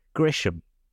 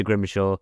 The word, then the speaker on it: Grimshaw: And then your next guest, Liam Grimshaw, although they kept calling him Grisham. Did you hear that? I don't know if they... – Yes, I did, yeah. – they refused to get his name right.